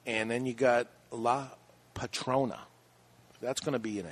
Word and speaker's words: Patrona: La P A T R O N A. Please don't spell it to me. I got to read it. Patrona?